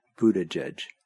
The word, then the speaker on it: Buttigieg: It had boot, like something you put on your foot, and then edge, like the edge of a cliff, and then another edge. And that's how you're supposed to pronounce it. Buttigieg.